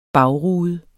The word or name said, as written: bagrude